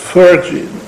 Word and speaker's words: Phrygia: This is an ancient mythological story which was played out in Phrygia. Could you write this down? P H Y R G I A. Phrygia. After the R, there's an A. Yeah? Phrygia.